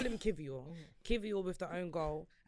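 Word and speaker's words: Kiwior: I don't know how. So is it a V? – I think the Polish pronunciation is Kivior. Yes. Yeah.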